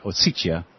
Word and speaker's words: Ossetia: the guy who dropped bombs on South Ossetia, that's how I'm hearing Russians pronounce it, Ossetia,